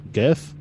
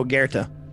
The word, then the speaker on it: Goethe: So the Earl King comes from a poem Goethe. – Oh, Goethe. – Yeah, totally. There's an R in there. – It's just how it's pronounced. I know Goethe.